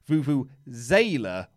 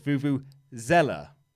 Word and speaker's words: Vuvuzela: The Z sound in Vuvuzela is like zebra, not zebra, as our American friends will insist upon. However, you guys have been pronouncing it Vuvuzela, which is wrong. It's Vuvuzela.